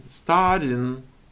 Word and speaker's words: studying: Studying. – See, it sounds like you're saying, I'm starting. This word has three syllables, okay? Between the D and the Y, there's a hidden strong Y.